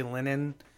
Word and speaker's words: Linehan: There's a guy named Graham Lennon, uh, and I say Lennon is it's spelled L I N E H A N, and he's the guy who he's made some of the best TV shows in the history of television.